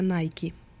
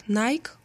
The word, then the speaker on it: Nike: а Nike.